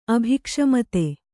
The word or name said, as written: ಅಭಿಕ್ಷಮತೆ